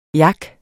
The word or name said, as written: yak